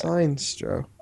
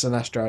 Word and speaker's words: Sinestro: Seinstro. – I've got a bad case of the Seinstros. – Yeah. I know it is pronounced Sinestro. I mean, Wikipedia even says it's pronounced Sinestro.